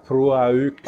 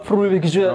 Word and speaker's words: Proulx: Och där går han igenom Annie Proulx, som jag har fått reda på att uttalas nu, som har skrivit... – Det stämmas typ Proulx. – Ja, Proulx, som ett franskt konstigt ord. Mm. Då är liksom de sista orden blir stumma.